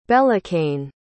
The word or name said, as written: belakane